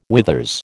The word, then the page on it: withers: American English